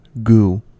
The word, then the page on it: goo: American English